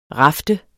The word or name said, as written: rafte